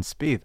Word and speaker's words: Spieth: because I want to use him because he rhymes with Jordan Spieth,